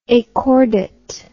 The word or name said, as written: achordate